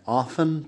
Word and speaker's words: often: Lo que pasa aquí, hay ciertas personas que sí pronuncian la T en often, pero por lo general no. Puedes escucharlo como often, pero por lo general la mayoría de las personas no pronuncian la T ahí, así que tienes often.